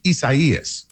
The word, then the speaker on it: Isaias: So this is a Spanish form of Isaiah. So it's pronounced Isaias.